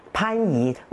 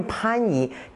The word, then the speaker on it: 番禺: 还有我们有个地名很典型的，大家都很熟悉的番禺，为什么不能念番禺呢？它是念番禺，地名还有人名，还有一些族名，它都要保留上古的读音，所以这个是非常古老的，念番禺不能念番禺，所以这个是第一点，这是从语音上来说保留了这个重唇音啊。